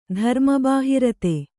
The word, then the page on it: ಧರ್ಮಬಾಹಿರತೆ: Kannada